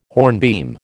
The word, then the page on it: hornbeam: American English